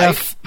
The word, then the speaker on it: GIF: But everyone's concerns are about the pronunciation of the G in the beginning. So, jife. – Nah, no. – No? – It's a gif. Jife. It's always jife.